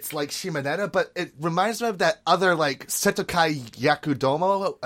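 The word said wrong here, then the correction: Seitokai Yakuindomo: I think that's how you pronounce it. – Yes, Setokai Yakuindomo.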